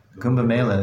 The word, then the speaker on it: Kumbh Mela: I'm saying this correctly, please correct me. The Kumbh Mela, Mela Kumbh? – Kumbh Mela in India? – Yeah.